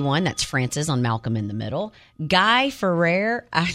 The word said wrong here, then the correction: Fieri: Yeah, Guy Fieri.